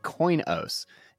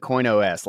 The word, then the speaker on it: Coinos: We have got Adam and Cole from, you said Coinos. Is that how you say it? Because I've been saying CoinOS, like it's like an operating system. Is it Coinos? – I was calling it CoinOS for a while.